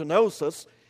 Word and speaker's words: gnosis: The word knowledge in 2 Peter 1 and verse 5 is translated from a Greek word gnosis, G-N-O-S-I-S, gnosis, a silent G as you might have seen, and it is a noun.